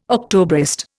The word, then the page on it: Octobrist: Indian